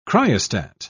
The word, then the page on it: cryostat: British English